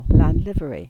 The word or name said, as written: Lanlivery